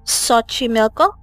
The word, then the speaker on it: Xochimilco: And just another fun fact, Xochimilco is, I don't know if I'm saying that right, it's X-O-C-H-I-M-I-L-C-O. So it's quite a name that's kind of hard to pronounce, but my brain says it's Xochimilco, Xochimilco. This is where the Aztecs,